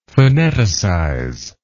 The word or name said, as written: phoneticize